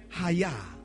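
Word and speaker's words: haya (חיה): The word revive is coming from the Hebrew word haya, which is spelled C-H-A-Y-A-H. The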